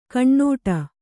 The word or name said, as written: ಗಿಣ್ಣಿಡು